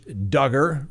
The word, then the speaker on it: Duggar: On a little bit of a side note, Josh Duggar, rhymes with hugger, he was part of his family's TV show.